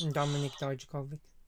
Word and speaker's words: Dominic Dijakovic: Dominic Dijakovic.